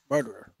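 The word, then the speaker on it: Marterer: How'd you pronounce that? Maximilian Mardier? – Marderer. Yeah.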